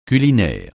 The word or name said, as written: culinaire